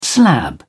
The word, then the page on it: slab: British English